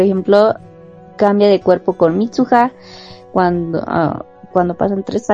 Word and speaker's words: Mitsuha: Esta película trata de, bueno, tenemos dos personajes principales que son Taki y Mitsuya, Mitsuha, Mitsuha.